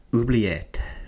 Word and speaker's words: Oubliette: Okay, so let's move on then from The Walk and John Scheiben, and let's go on to Oubliette.